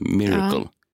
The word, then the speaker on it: Myrkl: Så är det rätt? Miracle. – Ja, Miracle. – Inte Miracle. – Jo, Miracle. – Ja, precis, men det stavas ju M-Y-R-K-L. Alltså, du uttalar det som det stavas, tror jag. – Ja. – M-Y-R-K-L.